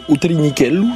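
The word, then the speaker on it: U Trinichellu: C'est un tout petit train qui s'appelle Utelinichelu. Je me permets de le prononcer comme ça, puisque j'ai moi-même une maman en Corse.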